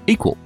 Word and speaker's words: EQL: That's EQL, pronounced equal.